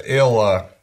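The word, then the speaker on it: Ælla: Какой-то британский королишка, видимо, Аелла. – Элла. – Элла. – Элла. Ну, АЕ, оно считается как Э. Элла. Это дифтонг. А Элла – это король Нортумбрии.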